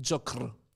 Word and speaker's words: Τζόκερ: Την Τζόκρ. – Και εμείς Τζόκρ θέξουμε... – ή Τζόκερ, πώς προφέρετε. – Τζόκρ. – Τζόκρ. – Τζόκρ. Εγώ δεν την πω Τζόκερ.